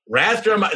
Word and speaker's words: Rathdrum: Rathdrum, did I say that right, Diane? – You did, all 6,000 of us.